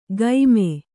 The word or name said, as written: ಗೈಮೆ